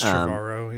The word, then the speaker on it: Trevorrow: I mean, you really could see why Spielberg chose this kid, Colin Trevorrow, or whatever you say his name, Trevorrow. It's like Trevor Tomorrow combined. – It's Trevorrow, yeah.